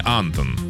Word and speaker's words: Антон: На А ударение. Антон.